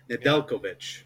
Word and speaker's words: Nedeljkovic: But Alex Nedlojovic. Okay, that guy. – I'll help you here. Nedeljkovic.